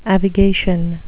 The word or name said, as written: avigation